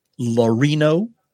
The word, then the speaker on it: Laurino: Her name is Katie Laurino. – Rhymes with Dan Marino.